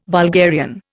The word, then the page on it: vulgarian: Indian